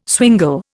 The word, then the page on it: swingle: Indian